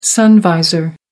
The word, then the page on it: sun visor: American English